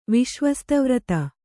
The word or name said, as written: ವಿಶ್ವಸ್ತವ್ರತ